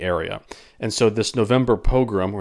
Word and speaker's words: pogrom: Now, it sounds like the word program, but it's actually a pogrom, P-O-G-R-O-M. And a